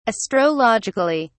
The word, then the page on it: astrologically: British English